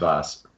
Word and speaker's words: Vass: And it is Vass, right? Not Vass. – Vass. Yes, Vasilis Vass. Is the full pronunciation.